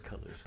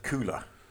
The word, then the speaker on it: Color: No, yes, I was just this because I pronounce it cooler, but I think it is colour, isn't it? – I think so. – Cooler. – It's not a fridge. It's colour thing. It's colour spelled American like.